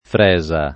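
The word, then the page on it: fresa: italiano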